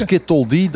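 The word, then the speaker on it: SCTLD: Skittle D, dat is dus een makkelijke manier om uit te spreken van Stony Coral Tissue Loss Disease.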